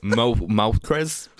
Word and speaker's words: Moltres: Excuse you. It's pronounced Moltres.